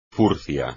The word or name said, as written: furcia